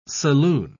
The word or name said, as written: saloon